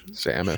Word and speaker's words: Samus: How do you say her name? Samus? Seamus? – Samus.